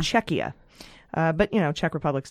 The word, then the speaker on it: Czechia: According to a patron who is in the Czech Republic is still a valid name, but the pronunciation is Czechia.